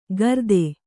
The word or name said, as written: ಕರ್ತೆ